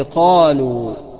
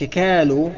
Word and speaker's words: قالوا: القاف زي قالوا، قالوا، ما تقولش كالوا انما قالوا.